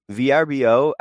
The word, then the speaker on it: VRBO: VRBO, I think you say it like Vrbo, right, Mike? I think you did that right.